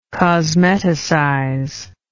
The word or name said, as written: cosmeticize